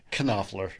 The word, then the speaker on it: Knopfler: But Mark Knopfler. – It's okay. At the end, it's spelled silent K. – Knopfler. – Knopfler. Mark Knopfler. – It's like Pfeiffer.